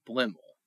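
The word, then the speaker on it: Bliemel: He said it's the best, the pronunciation is Blimmel. – Okay. – And it's spelled, well, depending on who you read, it's spelled different ways. Blimmel. But, yeah, Brother Bruno said that it's pronounced Blimmel.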